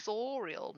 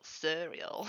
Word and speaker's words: Suriel: It's sorial, not surreal.